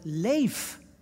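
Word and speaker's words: leef (לב): Dat is het woord leef. Het klinkt zo Nederlands, maar het is natuurlijk Hebreeuws. Leef, je schrijft het L-E-B, leb, maar die B spreek je uit als een V.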